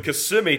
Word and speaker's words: Kissimmee: The Kissimmee chain. not chain. from America. Sorry about Terry's that. A, Terry's yeah, a foreigner from California by now in Cuba. Tennessee. – so the Kissimmee chain uh, of lakes, and that's where stuff is going to.